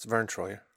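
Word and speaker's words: Verne Troyer: So then you pull up their cards that are provided on the website with actually Mike Myers' height and Vernay Troyer's height. – Vern Troy. – What? – It's Vern Troyer.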